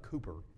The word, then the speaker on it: Cowper: The song was written by a man by the name of William Cowper, Cooper, spelled Cowper, C-O-W-P-E-R, but pronounced Cooper.